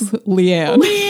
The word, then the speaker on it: Llyan: When you're reading the book, so the Welsh names are not necessarily pronounced the way that you think you are if you're an American like me. – Yeah. – So Leanne is spelled L-L-Y-A-N, so